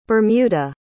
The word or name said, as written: bermuda